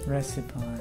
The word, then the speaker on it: recipe: Recipe. Hmm. – Recipe, bueno. – The correct pronunciation is recipe.